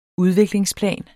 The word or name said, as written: udviklingsplan